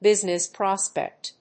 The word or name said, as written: business prospect